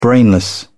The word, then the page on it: brainless: British English